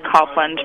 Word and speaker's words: Copeland: Well, it's actually pronounced Copeland, but I'll let that go, as long as you spell it correctly.